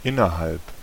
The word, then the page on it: within: American English; [wɪ.ˈθɪn]; /wɪðˈɪn/